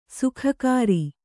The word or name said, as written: ಸೋಗುಗಾರ